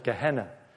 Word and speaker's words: Gehenna: The Greek word there, hell, is Gehenna, which is pronounced in English Gehenna.